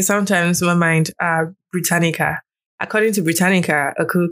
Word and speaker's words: Britannica: And according to uh, Brit- Brit- Britannia, Brit- Britannia, I'm sorry, I don't know how to pronounce this word very well. Uh, uh, apologies. – Britannica.